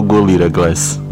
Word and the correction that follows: Gorilla Glass: Então, Gorilla Glass é o seguinte, ele foi a primeira. Vou lembrar disso pra sempre, cara. Eu falei certo agora.